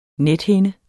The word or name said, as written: nethinde